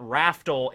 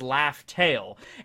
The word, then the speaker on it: Laugh Tale: Like, if nothing else, we learned in that movie before in the manga the way that Laugh Tale was actually supposed to be pronounced and spelled. It wasn't Raftel, it was Laugh Tale.